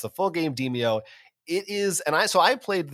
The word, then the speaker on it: Demeo: I believe that's how you pronounce it. It's Demeo. I think it's kind of a bad title because I kept thinking it looks like the word demo.